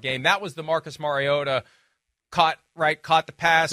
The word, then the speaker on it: Mariota: It's Mariota.